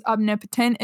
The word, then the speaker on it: omnipotent: On Google, it says omnipotent is the correct pronunciation.